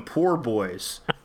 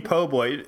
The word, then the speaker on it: po' boy: I will insist on calling them poor boys. Hi, could I have a shrimp poor boy? You mean po' boy. Yeah, if you have bad pronunciation of the English language.